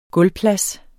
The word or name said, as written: gulvplads